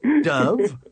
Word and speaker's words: Dov: For those of you listening, that is D-O-V-E, Dove, and my name is Dove, D-O-V, pronounce the O.